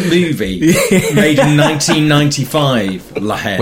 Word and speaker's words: La Haine: No, La Haine.